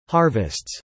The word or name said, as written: harvests